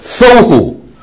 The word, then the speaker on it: vohu: 还有 Vohu wa Vohu 啊，我们再来读这个 Weha Aras 还有 Tha Vohu wa Vohu 啊，这个很啊，一定要把它记住，这个 formless 跟 anti 就是 Vohu wa Vohu 是 V 的发音啊